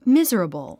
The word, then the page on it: miserable: míz ə rəbl; ミズラブル